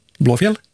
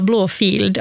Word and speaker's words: Blåfjäll: Blåfjäll? – Blåfjäll. Blå, det som folk brukar tendera att uttala Blåfield, alltså blå, alltså en F-I-E-L-D. Ja. Alltså en gammal stavning av ordet fjäll. Så det namnet är Blåfjäll.